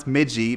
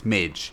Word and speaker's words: midgie: So the, the, the reason I pronounce midgie midgie is because it's a Scots word, and my gran is Scots, and so my mother said midgie, and but it's midge in popular parlance.